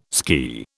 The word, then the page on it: ski: British English